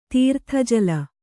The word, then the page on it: ತೀರ್ಥಜಲ: Kannada